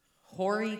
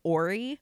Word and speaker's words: Horry: I I heard a couple of people pronounce it like Ori. Uh, yeah. But other people pronounced it with an a like Hori. So I'm.